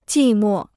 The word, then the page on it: 寂寞: jì mò